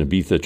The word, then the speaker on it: Ibiza: I also think Judas Priest had spent a year out of their minds on drugs and booze in Ibiza trying to make a commercially successful follow-up at a funny time in their career.